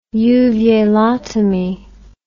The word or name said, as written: uvulotomy